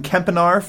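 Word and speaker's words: Kempenaar: We also have a list here from Adam Kempinar from Film Spotting. Is that how you pronounce his last name? – Yeah, very good.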